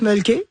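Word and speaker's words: Mölkky: Ça, on prononce mulky. – Ouais. – Et c'est M-O-L-K-K-Y.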